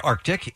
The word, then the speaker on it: Arctic: It is, in fact, Arctic, and I frequently say Arctic, and I can't figure out why.